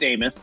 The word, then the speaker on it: Amos: Yeah, it's Amos. That's cool, Amos, though.